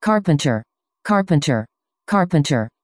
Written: karpenter

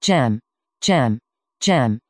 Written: gemm

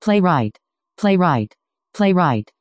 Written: playwright

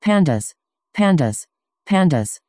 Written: pandas

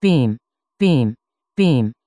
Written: beam

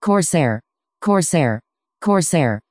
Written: corsair

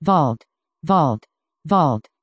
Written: vault

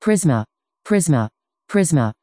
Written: prisma

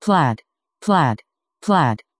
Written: plaid

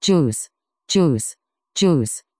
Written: guice

